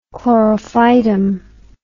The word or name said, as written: chlorophytum